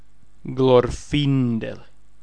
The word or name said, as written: Glorfindel